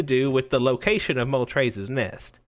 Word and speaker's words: Moltres: Excuse you. It's pronounced Moltres.